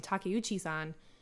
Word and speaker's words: Takeuchi: So it was, like, a huge deal that Takeuchi-san, like, actually, I just butchered her name, Takeuchi-san, did that to begin with.